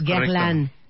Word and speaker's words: Guerlain: Director comercial de Guerlain. ¿Se dice bien? ¿Lo dije bien? – Sí, es Guerlain. Guerlain, Guerlain.